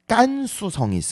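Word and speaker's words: 깐수성 (甘肃省): 깐수성이 있습니다.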